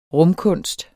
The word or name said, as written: rumkunst